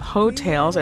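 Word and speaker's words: Heaux Tales: That was singer Jasmine Sullivan talking about her latest album, Hoetails, and that's spelled H-E-A-U-X, Tales.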